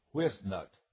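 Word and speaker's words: Whitsitt: It was called the correct pronunciation of the man's name was Whisnut.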